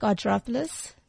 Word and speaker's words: Argyropoulos: It's Angelique Argyropoulos.